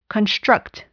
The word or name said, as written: construct